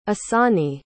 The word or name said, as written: Asani